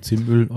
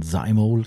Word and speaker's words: Zymol: Zimöl orientieren. Ja. Oder von Zymol, glaube ich, müsste man im Englischen richtig aussprechen, aber ich habe keinen Bock drauf.